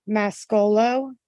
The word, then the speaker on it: Mascolo: Okay, my name is Christina with a K, Mascolo, M as in Mary, A-S-C-O-L-O.